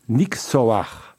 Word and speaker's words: Nyxoah: Nixoa, ça se prononce Nixoa. Nixoa, avec le H, c'est important c'est quoi Nixon dans le nom Expliquez-nous euh, déjà.